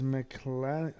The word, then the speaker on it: McClanahan: So, Tampa Bay's pitcher is McClanahan. – I don't know how to say it. That sounds absolutely perfect.